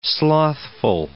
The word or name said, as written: slothful